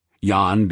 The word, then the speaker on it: Jan: Jan being pronounced like yawn.